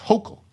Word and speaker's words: Hochul: New York governor's name, by the way, is pronounced Hochul, Kathy Hochul.